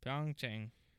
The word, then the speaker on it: Pyeongchang: This is from Wikipedia. Korean pronunciation in full. Pyeongchang. Gun. Pyeongchang.